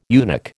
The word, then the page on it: eunuch: American English